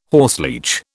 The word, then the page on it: horseleech: British English